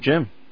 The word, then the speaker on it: Jim: Of course, we start off the show each week with Tim and the Technology News, and filling in for Tim is, rhymes with Tim, Jim.